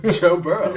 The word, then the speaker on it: Burrow: What, say his name again. – Joe Burrow? – No, that's not what you said. – What did I say? – You said, I'm going to go Burrows.